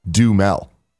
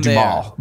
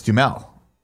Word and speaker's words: Duhamel: It's actually just Duhamel. You just don't pronounce the A at all. – Well, then why have him there? I've always said Duhamel, but it's Duhamel.